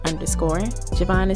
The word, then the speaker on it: Jovahn: Javon is spelled J-O-V-A-H-N.